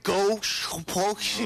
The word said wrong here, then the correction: gottepåse: No. Go, te, po, se.